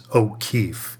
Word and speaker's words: O'Keeffe: And that's called Fireside Chats with Douglas O'Keeffe and my last name is spelled with two F's like the painter.